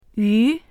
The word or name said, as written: yu2